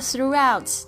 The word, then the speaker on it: throughout: 好，那这边这个 throughout 这边要特别注意，这个 g h 在这边是不发音的。